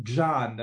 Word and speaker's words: Grzan: Das ist Pater Karel Gzan. Das wird wohl so ausgesprochen, G-R-Z-A-N, aus Slowenien.